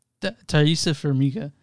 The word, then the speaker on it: Taissa Farmiga: But the first, starring as Irene, Tahisha Farmiga. – Taissa Farmiga. Actually, I was very close.